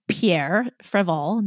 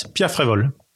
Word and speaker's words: Pierre Frévol: So I am here with Pierre Frévol. Now you say it. Did I say it correctly? – Pretty well, yes. Pierre Frévol. – Yeah. – It was a French accent.